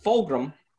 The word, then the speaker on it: Fulgham: and last fluker talent, two breakout candidates, Chase Claypool and Fuglam, can't even say his name, for you to say. – Of the Eagles. Eagles receiver Travis Fulgham, that's his name, had 13 targets, 10 receptions, 152 yards, 1 reception, and only his second game as an Eagle.